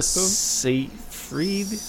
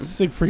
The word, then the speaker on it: Seyfried: Amanda Seyfried. How do you say her name? – Siegfried.